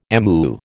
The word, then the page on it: amu: American English